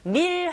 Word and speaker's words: meal: 이렇게 발음하시고, meal 할 때 여러분 그냥 meal이 아니라 meal 하고 혀끝이 윗니 뒤로 붙어주셔야 돼요. L 사운드, 다크 L 사운드잖아요.